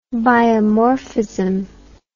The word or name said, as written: biomorphism